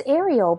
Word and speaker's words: Areel: It's Ariel, but it's spelled... – The Little Mermaid? – It's spelled A-R-E-E-L.